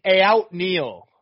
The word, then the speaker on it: Aoutneil: It's Aout Neil. – Yes. – It's spelled Aoutneil. So it is Neil, but it is there's some letters before it as well.